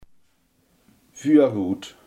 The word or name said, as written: voorruit